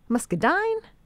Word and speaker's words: muscadine: But is it muscadine? Muscadine? – Muscadine, yes.